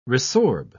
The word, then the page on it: resorb: British English